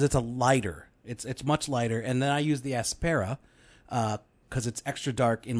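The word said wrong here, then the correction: Apsara: Okay, obviously I'm pronouncing it wrong. That's why. All right, how do you pronounce it? – Well, it's Apsera. – Yeah, Apsera, whatever.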